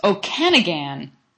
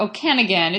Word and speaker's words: Okanagan: There's a company called Okangan. How do I pronounce that? Or Okangan. Do you know how to say it? It's Okanagan. I'm missing a syllable there. Okanagan.